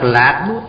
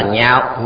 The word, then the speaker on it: nhạo: Chữ này đọc giọng cao, không đọc lạc mà đọc là nhạo, nhạo là ưa thích.